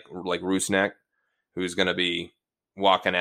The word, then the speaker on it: Rusnak: So that you can all be better prepared for my goal-scoring announcements this season, the pronunciation of our new designated player's name is Rusnak, Albert Rusnak.